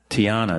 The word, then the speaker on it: Tiyana: Tiana. – Tiana, T-I-A-N-A? – T-I-Y-A-N-A.